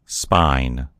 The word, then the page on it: spine: American English